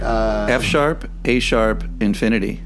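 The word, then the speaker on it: F♯ A♯ ∞: F-sharp, A-sharp, Infinity.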